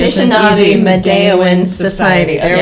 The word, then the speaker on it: Anishinaabe: I've been saying it a little bit wrong. – Okay. – Anishinaabe Medewin Society. Is that right? – Yeah. – Did I say it right? – The Anishinaabe Medewin Society.